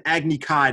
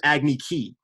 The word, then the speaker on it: Agni Kai: It's pronounced an Agni Kai, not an Agni Ki.